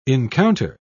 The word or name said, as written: encounter